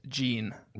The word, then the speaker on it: Jeanne: I also have a relative who spells it like this, and it's pronounced Gene.